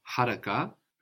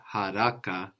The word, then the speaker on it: haraka: So whilst in Arabic we have haraka with the accent on the beginning in Swahili, we have haraka, no? The accent on the penultimate syllable.